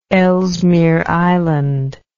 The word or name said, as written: Ellesmere Island